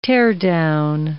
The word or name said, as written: teardown